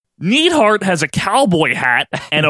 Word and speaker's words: Neidhart: Now he's Neidhart because the announcer said Neidhart when he announced him and he didn't want to look stupid.